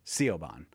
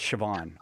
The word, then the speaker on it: Siobhan: Because I'm sure her entire life she's been called Siobhan because it looks like Siobhan, but it's Siobhan.